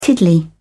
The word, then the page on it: tiddly: British English